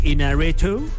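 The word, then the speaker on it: Iñárritu: Inarritu.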